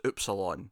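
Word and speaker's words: Ypsilon: When you go through the alphabet in German, you get to Y, and I think it was Upsilon. That's how you say it.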